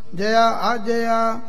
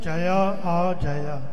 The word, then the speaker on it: जया आजया: जया आजया चरमणि चावडायं जया आजया चरमणि चावडायं इस तरह का उच्चारण करना है जया आ जया जिवें सिंह ने बोलिया इवें ही शुद्ध पाठ है जी